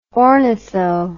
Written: ornitho

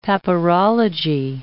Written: papyrology

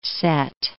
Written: Cette

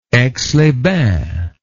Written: Aix-les-Bains

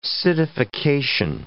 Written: citification

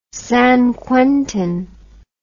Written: San Quentin

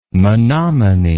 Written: menomini

